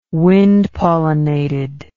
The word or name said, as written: wind pollinated